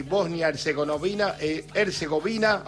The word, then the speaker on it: Bosnia-Herzegovina: allí en Toronto empataron Canadá y Bosnia-Herzegovina,